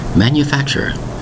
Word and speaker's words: manufacturer: Manufacturer. Notice we have four distinct syllables.